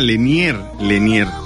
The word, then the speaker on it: Lenier: Leiner, se escribe Liner, ¿no? Ah, Lenier. Bueno, pues ahí está.